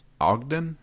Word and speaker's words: Ogden: His name was Ogden.